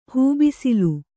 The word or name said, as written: ಹೆಬ್ಬಿಸಿಲು